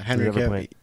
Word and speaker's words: Cavill: Yeah, Henry Cavill.